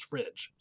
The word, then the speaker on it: fridge: So it should be F-R-I-G-E should correctly be pronounced fridge or normally pronounced fridge, which obviously doesn't really work. Put another consonant in, and then it becomes I. So then it becomes short again, so it becomes fridge.